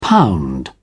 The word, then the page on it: pound: British English